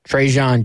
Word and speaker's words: Trajan: Because, I don't know, maybe even jumping ahead of ourselves, can you break down, is it Trahan or Trajan? – Trajan Jeffcoat is how it's always been.